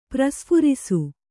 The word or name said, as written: ಪ್ರಸ್ಫುರಿಸು